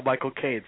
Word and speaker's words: Michael Caine: Say the phrase "my cocaine," and that's how Michael Caine says his own name.